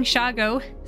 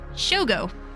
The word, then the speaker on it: Shoggo: It took me a long time to figure this out because I was reading Shago, spelled S H O G G O, as Shogo, as if it were Japanese or something. When you pronounce it Shago, it sounds a bit like Chicago, sped up or abbreviated, uh, abbreviated like Nolins instead of New Orleans.